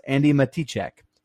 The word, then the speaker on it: Matichak: I've heard it both ways from people that have interviewed her, but the interview on Collider, which this makes me dumb because I call her Andi Matichak the whole movie, uh, the whole review, but the interview on Collider was Andi Matichek. So whoops. I've been saying her name wrong, and I'm in.